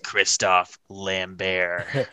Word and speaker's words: Christophe Lambert: Actually, it's pronounced Christophe Lambert. This is my French person voice.